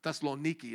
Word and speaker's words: Thessaloniki: They're actually from Thessalonica. Thessaloniki is how they say it.